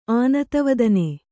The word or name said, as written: ಆನತವದನೆ